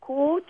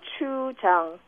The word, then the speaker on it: gochujang: It's gochujang. gochujang.